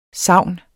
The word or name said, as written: savn